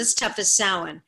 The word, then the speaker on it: Samhain: It's like the reason I really love Halloween 3 is because the actor pronounced Samhain correctly. – Love it. – So like every other one says Samhain as if he's like this guy called Samhain. And it really, really annoys me. And as an Irish person, it was so refreshing to actually have it depicted accurately and, you know, the Irish culture and things like that. So, yeah, it's pronounced Samhain, and he does it very good.